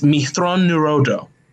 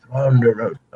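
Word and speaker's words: Mitth'raw'nuruodo: Well, you kind of have to say his whole name, really. And it's Mithron Nerodo. – Got it. – Mithron Nerodo.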